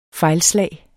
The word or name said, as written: fejlslag